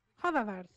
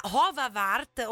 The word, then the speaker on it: Hovawart: Poi cane d'acqua spagnolo, che abbiamo studiato nel nostro compendio che trovate online, il Hovawart, non me lo ricordo assolutamente. – Hovawart si legge, Hovawart, ok, ma perché lui è tedesco? – Sì, secondo me sì, adesso mi documento.